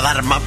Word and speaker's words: Dharma: como por ejemplo el Dharma F1, que es una semilla de un calabacín que se pronuncia Dharma, pero se escribe D-H-Arma, Dharma, con H intercalada, que se ha consolidado como una variedad referencia en calabacín en Almería.